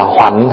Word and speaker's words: hoành: Chữ hoành á, có khi đọc là hoạnh hay là hoành cũng được.